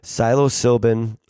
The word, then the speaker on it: psilocybin: The feds have designated Compass Pathways experimental psilocybin.